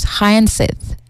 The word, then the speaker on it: hyacinth: But he is turning an invasive water hyacinth, that's how you pronounce it, I suppose, hyacinth, yes, into biodegradable packaging through his company that is called Haya Park Ecotech.